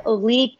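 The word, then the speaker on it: sambal oelek: And did you use the sambal, I don't know exactly how to say it, sambal olek from Haiphong? – Olek, potato, yes, that's what I used.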